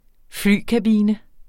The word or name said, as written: flykabine